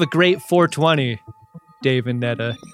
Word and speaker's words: Netta: Netta, it's pronounced like cheddar.